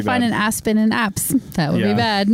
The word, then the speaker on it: apse: And I had to look up an apse. Am I pronouncing it right? APSE? – Yeah, apse. – Yeah. – Yeah. I thought I put the definition. – Not to be mistaken for an asp.